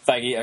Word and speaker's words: Feige: Yeah, I just read when I got home after seeing it on Slash Film that Kevin Feige, I don't ever remember how to say his last name. – Feige, okay, said that it is a scene from Civil War.